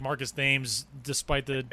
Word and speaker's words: Thames: And it's Marcus Thames. – He pronounces it the other way. He's like the river.